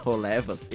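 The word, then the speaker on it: Holebas: Rolebas, que na verdade deve se chamar Rolevas, ele se escreve com CH, Cholebas. É. – Caraca, se eu, se eu tivesse esse nome, eu ia querer que me chamassem só, só Cholebas. no grego, algum na grafia, né, do, do H lá atrás, quando transfere para o alfabeto latino, fica com CH. Então você lê, né, nós, cholebas, mas você tira o C.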